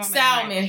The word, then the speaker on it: salmon: Salmon. – That. If you don't stop pronouncing that shit with an L, the L is silent.